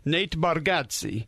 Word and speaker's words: Nate Bargatze: Excited to welcome in Nate Bargatze.